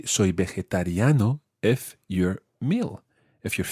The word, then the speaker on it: vegetariano: It is quite tricky to say. Try that syllable by syllable. Ve. – Ve. – Je. – Je. – Ta. – Ta. – Riano. – Riano. – Vegetariano. – Vegetariano.